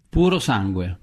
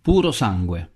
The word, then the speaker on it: Purosangue: Puro Sangue. – Again? – Puro Sangue. – Not catchy. P-U-R-O-S-A-N-G-U-E.